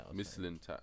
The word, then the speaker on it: Mislintat: How long has Sven Mislintat, how do you say his name? – Mislintat.